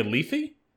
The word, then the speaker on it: Lethe: Uh, according to how to pronounce, uh, I am having a Lethe.